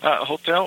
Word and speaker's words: hotel: Hotel also spells the same as English, but you just say hotel. You just don't pronounce the age as a, you know, like hotel. You just say hotel.